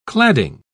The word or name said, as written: cladding